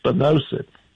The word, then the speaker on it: spinosad: He's been recommending this stuff called, is it spinosad or spinosad? – I was told it was spinosad.